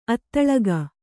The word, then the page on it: ಅಧೋಲೋಕ: Kannada